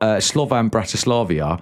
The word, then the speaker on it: Slovan Bratislava: And then you've got Slovan Bratislava. – Well done. – Perfectly pronounced.